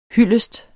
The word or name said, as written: hyldest